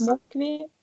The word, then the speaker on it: Москве: Man schreibt es Москве. Ja, man spricht es aus Москве.